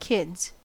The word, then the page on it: kids: American English